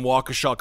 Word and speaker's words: Waukesha: Marcus, I do want to thank you for pronouncing Waukesha correctly.